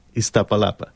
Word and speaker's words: Iztapalapa: How do you pronounce it, really? – How would you say it? – Universidad Autónoma Metropolitana Iztapalapa.